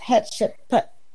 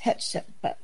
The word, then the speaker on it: Hatshepsut: And her name is pronounced Hatshepsut. – Thank you. – That's how I've heard it pronounced. Hatshepsut.